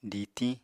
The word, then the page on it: ditiñ: apatani